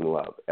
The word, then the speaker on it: Love: And that's my name is spelled Damian, last name Love, L-O-V-E.